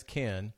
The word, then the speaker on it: Cannes: so I thought I knew what I was saying, but it turns out it's Cannes. Oops, I'm looking at the wrong camera. For all of you who want to say it right, of course, we already know not to say the S. – Right. – But I was getting the A wrong.